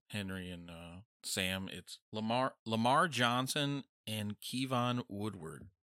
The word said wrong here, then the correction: Woodard: Or Woodard, sorry.